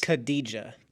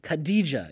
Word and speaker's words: Khadija: And you know how to pronounce her name. I'm not good with this. – Khadija. – Khadija, yeah. Khadija.